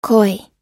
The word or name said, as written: koi